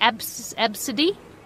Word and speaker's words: Abcde: Wasn't it absidy? – Absidy. – Yeah. – A, B, C, D, E. – It's like abstinence, but on a boat.